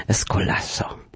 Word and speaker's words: Escolasso: Razem można akcentować, mogą Państwo poćwiczyć, ja podpowiadam Escolasso.